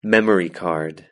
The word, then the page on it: memory card: British English